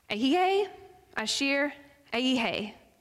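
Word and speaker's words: Ayihe, Ashir, Ayihe (אהיה אשר אהיה): In the original Hebrew, this phrase, I am who I am, is pronounced Ayihe, Ashir, Ayihe.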